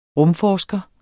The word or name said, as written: RUMFORSKERE